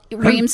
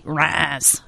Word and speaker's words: Reims: Reims France? – Well, Reims is fine, or I like to say Raz.